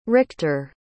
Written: richter

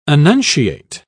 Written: annunciate

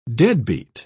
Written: deadbeat

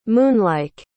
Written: moonlike

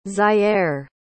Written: zy'aire

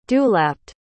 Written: dewlapped

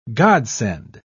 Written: godsend

